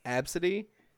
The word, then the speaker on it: Abcde: I think the worst non-celebrity one I've heard is it's pronounced Absidy, and it's literally just ABCDE.